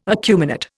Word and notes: acuminate: Indian

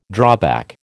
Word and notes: drawback: American English

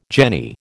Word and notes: jenny: American English

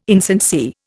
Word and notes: insincere: Indian

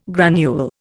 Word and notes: granule: Indian

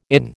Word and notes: inn: American English

